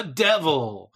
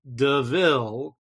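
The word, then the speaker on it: De Vil: It was like, oh, hey, look, this car's a devil. And the other thief corrects him. He's like, it's pronounced DeVille.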